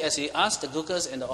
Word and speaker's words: Gurkhas: Gurkhas, how do you pronounce that? Well, Gurkhas, okay.